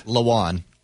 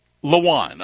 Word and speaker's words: Lewan: All right, let's get to this real quick because there's a podcast, as I was saying, hosted by Titans offensive lineman, Taylor Lewan, Lowan. How do you say it? – Lowan. – Lowan. Okay. And former Titans linebacker Will Compton, they had on, it's called, Bussin' with the Boys.